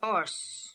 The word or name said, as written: Ors